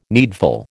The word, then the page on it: needful: American English